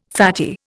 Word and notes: fatty: Indian

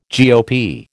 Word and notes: GOP: American English

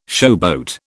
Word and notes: showboat: British English

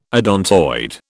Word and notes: odontoid: British English

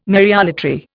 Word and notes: Mariolatry: Indian

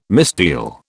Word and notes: misdeal: British English

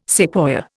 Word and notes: sequoia: Indian

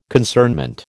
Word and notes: concernment: American English

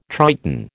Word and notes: Triton: American English

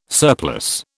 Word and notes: surplus: British English

